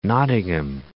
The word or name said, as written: Nottingham